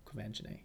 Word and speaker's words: Quvenzhané: Quvenzhané. – Quvenzhané. Isn't that how it's pronounced? – Yeah.